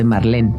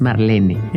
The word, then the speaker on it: Marlene: como Marlene Dietrich, por ejemplo, que todo el mundo dice Marlene, pero es Marlene.